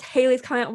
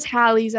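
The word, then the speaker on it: Halley's Comet: If it's pronounced Haley's Comet, why is it spelled like that? I was very confused. – I thought it was Hallie's at first.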